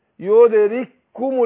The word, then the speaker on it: يدرككم: لا يدرككم لا احنا عندنا الكاف تضغم في الكاف الاولى تضغم في الثانية هننطق كاف واحدة مشددة فاقول اينما تكونوا يود يدرككم الموت يدرككم الموت كاف واحدة.